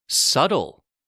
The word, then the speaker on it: Subtle: If you have a B and a T together, the B is silent too, sometimes. I will give an example. Lamb. L-A-M-B. Subtle. S-U-B-T-L-E. That's right. Subtle. Subtle is spelled S-U-B-T-L-E.